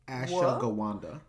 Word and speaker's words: ashwagandha: Yo, I started taking ashwagandha. Did I say it right this time? – Ashwagandha? – Ashwagandha. – Ashwagandha. Yes.